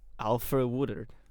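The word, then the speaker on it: Alfre Woodard: Alfred Woodard.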